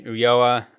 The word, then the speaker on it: Ulloa: Yeah, on Twitter, at Brady Ulloa. That's B-R-A-D-Y. Last name is spelled U-L-L-O-A, which is a little tricky.